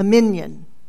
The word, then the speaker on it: minyan: A minyan, the Hebrew word for reckoning. It's a reckoning. And it's spelled M-I-N-Y-A-N.